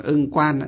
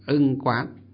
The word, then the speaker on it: ưng quán: Chúng ta hay đọc là ưng quan, nhưng thực tế nó là ưng quán. Nhưng chúng ta đọc cho nó quen, hay đọc quen là ưng quan pháp giới tính, nhất thiết duy tâm tạo.